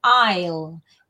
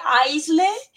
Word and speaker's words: aisle: Aisle. Cuidado que se escribe aisle, pero no se pronuncia ni la S ni la E final.